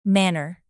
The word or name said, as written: manor